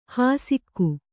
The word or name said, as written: ಹಾಸಿಕೆ